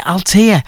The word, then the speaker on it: Altea: Is that what, how you say it? Is it Altea? Well done to you, Helen. – Correct.